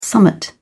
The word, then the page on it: summat: British English